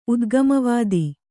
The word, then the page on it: ಉದ್ಗಮವಾದಿ: udgamavādi